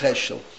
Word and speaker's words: Heschel: Heschel, it's Heschel. Hey Shin Yud Lamed is the way it's spelt in Hebrew.